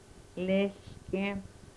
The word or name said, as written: lehlkem